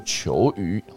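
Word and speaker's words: 犰狳: OK，好有趣哦，我今天长知识了，不然之前都不知道球鱼要怎么念哦，一个犬字边一个九，一个犬字边一个雨天的雨，哈，它叫做球鱼哈，球鱼可以缩成一。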